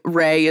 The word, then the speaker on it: Rae: And you can follow me, Hannah, at Hannah Ray Leach on everything. Ray is spelled R-A-E, if that helps.